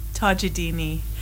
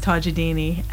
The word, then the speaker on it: Tajoldini: So, Tajoldini. – Tajoldini. – Even though it's spelled like... I'll tell you how it's spelled. It's Tajoldini, but it's pronounced Tajoldini, and not a lot of people can say it, so Tajoldini.